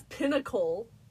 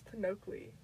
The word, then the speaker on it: Pinocchio: I've been pronouncing it as Pinnacle. – Pinocchio.